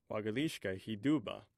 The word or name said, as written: wagthishka hiduba